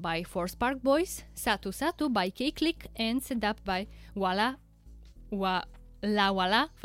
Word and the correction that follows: La Wala Familia: I said it correctly before the break, and now I messed it up again. Let me try it again. La Wala Familia. That's it.